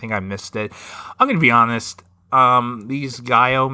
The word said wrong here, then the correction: giallo: mysteries, is that how you pronounce it? – Gaio? Giallo. – Giallo.